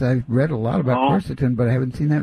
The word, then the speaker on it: quercetin: I don't know how you pronounce it. Quercetin? – Quercetin? – Yeah. – Ah, that's it, quercetin.